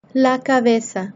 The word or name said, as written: la cabeza